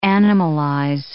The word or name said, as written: animalize